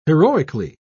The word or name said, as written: heroically